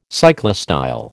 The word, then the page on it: cyclostyle: American English